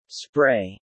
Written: spray

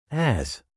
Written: as

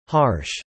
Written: harsh

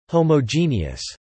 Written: homogeneous

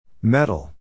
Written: metal

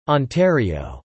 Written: Ontario

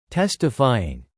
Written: testifying

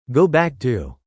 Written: go back to

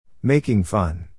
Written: making fun

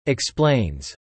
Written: explains

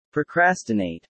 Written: procrastinate

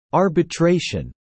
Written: arbitration

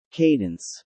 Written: cadence

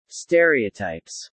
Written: stereotypes

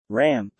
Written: ramp

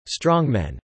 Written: strongmen

